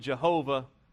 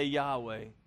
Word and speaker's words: Yahweh: In more modern times, they've pronounced it Jehovah after a German pronunciation, but the Jews would say Yahweh or Yahweh, depending on how they inflect the W there.